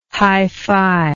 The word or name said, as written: hi-fi